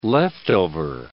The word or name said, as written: leftover